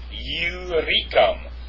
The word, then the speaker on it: Illyricum: So we do know that there is no U, or there is no Y, pardon me, in the Greek, and so that and iota or Iode. It's not Iode. It's Iota. So this is pronounced Illyricum.